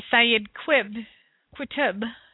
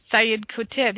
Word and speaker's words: Sayyid Qutb: The other leader, which is Sayyid Qutb, is how you say it. Sayyid Qutb, he lived between 1906 and 1966.